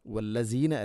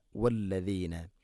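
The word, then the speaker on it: വല്ലദീന (والذين): വല്ലസീന അല്ല വല്ലദീന. വല്ലദീന ദാല് നാവിൻ്റെ അറ്റം മുൻപല്ലുകളുടെ ഇരുനരകൾക്കിടയിലൂടെ അല്പം പുറത്തോട്ട് വരണം, അതാണ് ദ.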